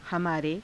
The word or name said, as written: hamaare